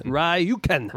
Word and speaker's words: Rjukan: It's R-J-U-K-A-N. How would I say it? Rjukan. – Rjukan. Oh, my God.